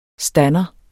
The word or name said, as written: stander